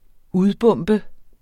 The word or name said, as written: udbombe